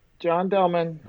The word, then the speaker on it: John Delman: Yep, John Delman. – Awesome. – D as in David, E-L-M-A-N.